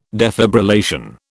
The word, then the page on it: defibrillation: British English